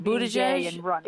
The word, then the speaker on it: Buttigieg: Buttigieg? – How do you think he'll do Buttigieg? – I mean, he's great.